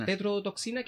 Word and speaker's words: tetrodotoxina: Tetrodotoxina que sacaba de.